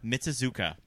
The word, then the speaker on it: Mitsuzuka: Hiko, do you mind pronouncing your last name? I feel like I always mess it up. – Mitsuzuka. Yes.